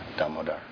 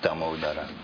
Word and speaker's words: Damodara: That's a very famous name of Krishna, Damodar. Damodara. Well, in English we say Damodar, but the proper pronunciation is Damodara.